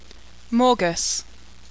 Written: Morguss

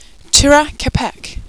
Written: tura kepek

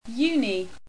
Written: uni

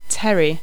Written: Pterii

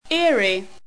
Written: eyrie

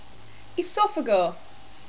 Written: esophagor

